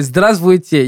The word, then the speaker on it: здравствуйте: Например, здравствуйте, есть вы, который не читается что еще? – Чувствовать.